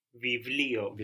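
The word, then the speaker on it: Vivlio (βιβλίο): Vivlio.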